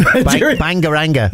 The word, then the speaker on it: Bangaranga: Bangaranga. – With a hard G. – With very hard G. – Bangaranga.